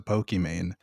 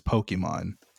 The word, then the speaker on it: Pokimane: Sure, but the discussion that we've had is specifically that she allowed people to mispronounce and whitewash her name, and it changed the pronunciation to Pokimane when it's supposed to be pronounced the same as Pokemon.